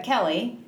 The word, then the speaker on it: Kallay: Sometimes it's Kallay, sometimes Kallay. But Kallay. People say both, yes. – Okay, so how do you say it? – I say like Kallay, but anyway, but people say Kallay, so yeah.